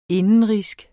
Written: indenrigsk

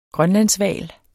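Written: GRØNLANDSHVAL

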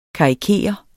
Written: karikere

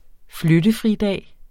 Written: flyttefridag

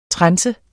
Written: trense

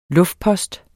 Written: luftpost

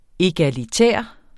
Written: egalitær

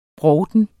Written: brovten

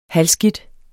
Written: halvskidt

